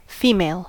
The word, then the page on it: female: American English